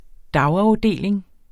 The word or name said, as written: dagafdeling